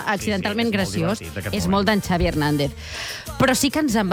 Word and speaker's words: Xavi Hernández: Xavi Hernández.